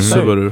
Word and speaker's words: Субару: Это даже никто не поправляет, потому что ну, никто, ни, никто не говорит правильно Субару, как бы, потому что там надо на последний слог ударение Субару.